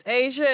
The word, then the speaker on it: Asia: My name is Asia.